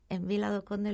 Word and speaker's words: Vila do Conde: Que además, como están en Vila do Conde, lo he dicho bien, ahora sí. – Sí, sí. – Que ya me estaba yo. Digo, pues como está en Vila do Conde, y en Vila do Conde pues hay mucha tradición por el pescado, ¿tá?